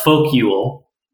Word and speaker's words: Folkjul: Yeah, I have to say the next one that immediately comes to my mind is Folk Yule. So that's spelled F-O-L-K-J-U-L.